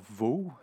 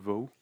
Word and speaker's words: Waugh: Alors, c'est un film d'action américain réalisé par Scott euh, je vais dire Vaux, ça s'écrit W-A-U-G-H, donc ouais, Scott Vaux, ou Scott Wow, puisque c'est euh, américain.